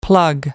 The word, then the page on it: plug away: American English